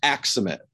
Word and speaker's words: Aksamit: Close, Aksamit. Think Aksamit, the okay. Yep. Stress on the X.